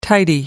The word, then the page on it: tidy: American English